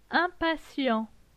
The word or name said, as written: Impatient